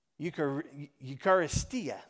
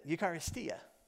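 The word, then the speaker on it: Eucharistia (εὐχαριστία): And in the New Testament, the word for that, the Greek word for that is, I'm going to say it right, Eucharistia, that's it, Eucharistia.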